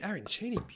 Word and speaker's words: arancini: And you're like, mate, arancini, am I pronouncing that right? – Yeah.